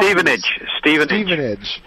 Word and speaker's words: Stevenage: The league gets put to the side, and Everton has Stefanage, a very interesting away tie for the top. – Stevenage. – Thank you. I mispronounced it.